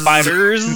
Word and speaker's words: FighterZ: That's what the way it should be pronounced. Dragon Fighters. Ball Fighters. And you have to give it a hard z.